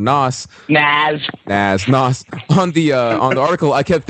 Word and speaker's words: Nas: Softer S, so like Nas. – Nas. It's voiced. The S is voiced. It's okay. – Like a Z.